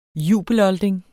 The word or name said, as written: jubelolding